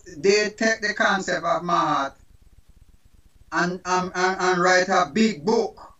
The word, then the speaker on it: Maat: The African concept of spirituality is the concept of Mahat. Mm-hmm. It's spelled M-A-A-T, Mahat.